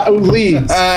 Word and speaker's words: Ledes: Well, my dad used to say anything but lead ass. Leeds.